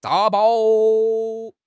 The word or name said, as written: 打包